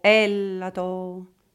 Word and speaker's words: Έλατο: «Ο Έλατο», «Ο Έλατο». Ευτυχώς που το «Έλατο» στα ελληνικά τονίζεται στην πρώτη συλλαβή.